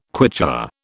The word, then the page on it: Quichua: British English